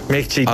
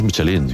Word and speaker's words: Michelin: Michelin. A ver, aquí en la Península Ibérica hacemos Michelin.